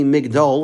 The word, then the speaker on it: Migdol: Now it's written Magdil, and in Tehillim it's actually pronounced Magdil, like we say during the week. But here the Kri is actually Migdol, like we say on Shabbos.